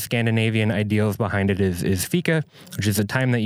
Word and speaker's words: fika: And that name, Fika, am I saying it right? – No, Fika. – Fika. – Yeah, it's Swedish for coffee.